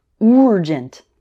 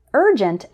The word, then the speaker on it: urgent: A very common mistake that I've corrected for a number of students involves words like urgent, which they might pronounce with a strong U sound like urgent. But urgent and urban actually start with more of an R sound, like in bird or early.